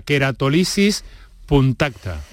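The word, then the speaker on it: queratólisis punctata: que es la queratolisis puntacta. ¿Lo he dicho bien? – Sí, perfectamente.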